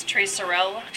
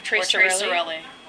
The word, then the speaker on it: Tre Sorelli: Do you pronounce the E at the end? – In Italian, you pronounce every letter. – Okay. – It's not like French where you just kind of trail off. – Okay. – So I'm guessing it's Tre Sorelli.